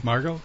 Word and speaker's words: Margot: M-A-R-G-O-T. – Margot? – No, G-O-T. – I don't think they pronounce Margot. – The T.